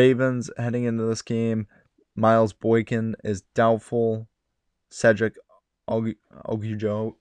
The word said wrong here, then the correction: Ogunjobi: I cannot pronounce the name right now. I'm so sorry. Cedric O, the tackle, Ogunjobi, there we go, is out.